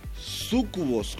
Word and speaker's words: súcubos: Las mujeres no eran más que súcubos. ¿Cómo se pronuncia esto? – Súcubos.